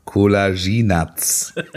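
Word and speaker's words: Kolašinac: Der heißt Kolaschinatz.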